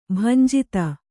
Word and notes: ಭಂಜಿತ: bhanjita

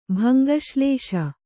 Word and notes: ಭಂಗಶ್ಲೇಷ: Kannada

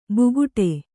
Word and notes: ಬುಗುಟೆ: buguṭe

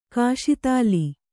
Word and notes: ಕಸ್ತಲೆ: kastale

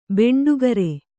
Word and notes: ಬಂಡಿಕಾರ: baṇḍikāra

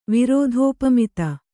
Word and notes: ವಿರೋಧೋಪಮಿತ: Kannada

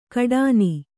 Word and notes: ಕಡಾನಿ: kaḍāni

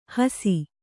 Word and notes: ಹೂಸು: kannada

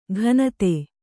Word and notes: ಘನತೆ: kannada; ghanate

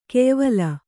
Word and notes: ಗೆಯ್ವೊಲ: Kannada

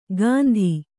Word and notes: ಗಂತಿ: Kannada

